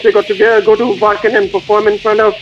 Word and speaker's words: Wacken: at Wacken Metal Fest.